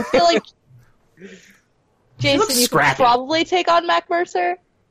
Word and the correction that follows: Matt Mercer: Did I say his name right? – Matt Mercer.